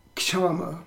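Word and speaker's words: Kshama (क्षमा): Then the next one is called kshama, K-S, diacritical mark, Uchi, A-M-A, Kshama. It could be pronounced Kama also, in different pronunciations of Sanskrit.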